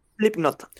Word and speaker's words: Slipknot: El ex baterista de la banda estadounidense de heavy metal Slipknot, Slipknot, ¿cómo se pronuncia? Perdón, no quiero faltar respeto. Slipknot. Perfecto.